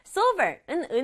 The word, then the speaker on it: silver: Silver은 은색 또는 은색의 꾸미는 말도 돼요. 우리말로는 silver. 들어봤죠? silver. silver인데 영어로는 so 소리 살려서 silver. silver이라고 해요. 발음 정확하게 silver.